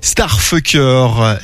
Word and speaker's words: STRFKR: Ça s'écrit S-T-R-F-K-R, mais il faut prononcer Starfucker.